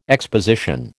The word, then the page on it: exposition: American English